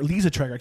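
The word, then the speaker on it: Liza Treyger: By Hampton Young and Liza Traeger. Lisa Traeger. I keep saying Liza because it's spelled Liza, but it's Lisa. Lisa with a Z.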